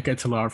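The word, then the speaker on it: De Ketelaere: It's like De Kuliar from Club Bruges in Belgium. – Yeah, the Ketelaar from Bruges.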